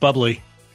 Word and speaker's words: Bublé: It's pronounced bubbly.